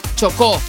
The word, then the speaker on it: Chocó: Chocó.